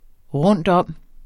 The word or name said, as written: rundtom